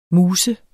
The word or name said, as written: MUSET